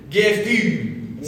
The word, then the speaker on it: Gefühl: Gefühl.